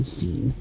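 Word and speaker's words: Xeen: Z-E-E-N. X-E-E-N, I mean. Zine. – Oh, okay. – That's what it's pronounced. Zine.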